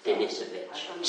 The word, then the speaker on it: Denisovich: Denisovich.